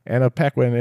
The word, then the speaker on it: Anna Paquin: It's got a quick casting of Zach Levi, Annie Paquin? I always say her name. Anna Paquin and Dennis Quaid.